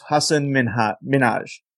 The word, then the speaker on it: Hasan Minhaj: I don't know who the hell that is, because you're not pronouncing his name right. I think it's just Hasan Minhaj.